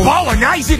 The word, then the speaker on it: bolognese: Bolognese in a Jaffel? Bellissimo! – Joe, it's pronounced bolognese. – That's what I said, bolognese.